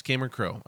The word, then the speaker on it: Cameron Crowe: Yes, Cameron Crowe.